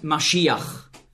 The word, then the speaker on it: Maschiach: In meiner Bibel steht Messias, weil im Grundtext hier das Wort Maschiach, so ist die hebräische Aussprache, zu finden ist.